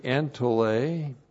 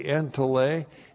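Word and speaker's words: entole (ἐντολή): Already, entole, E-N-T-O-L, long E, entole.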